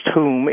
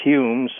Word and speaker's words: Home: Yeah, just so in case some of the listeners don't know, when I say Hume, I mean, his name is spelled Home, H-O-M-E. In Scotland, it's pronounced Hume, H-O-O-M, and in England, it's Hume, so just so we know, we're talking about the same person.